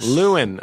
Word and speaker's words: Llewyn: Llewyn. I've been saying it correctly.